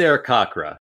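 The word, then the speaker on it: Arakkoa: Well, to be that fucking nerd, I do think it's Arak Okra.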